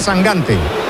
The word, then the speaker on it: Sangante: Sangante, se pronuncia Sangante. – Yo pronuncio como me digáis. – Bueno, eso he leído yo, que dice que es Sangante. – De hecho, me ha dicho, tú acuérdate de Mangante.